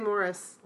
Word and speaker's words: Maurice: So in my perfect world, I would say if it's really pronounced Morris, it should be spelled M-O-R-R-I-S, right? – Yes. Yeah. – But it's spelled M-A-U-R-I-C-E.